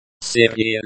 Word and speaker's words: серия: Серия лекций. Серия лекций. Серия. Серия. Means, series, in this context. The, I, at the end is pronounced like the, E, in, C, серия.